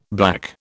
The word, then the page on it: black: British English